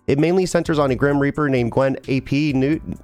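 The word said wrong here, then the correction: Gwen ap Nudd: Did I say that right? – Gwen Abnud. – Abnud, thank you.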